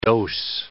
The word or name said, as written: dose